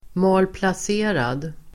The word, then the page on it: malplacerad: [malplas'e:rad]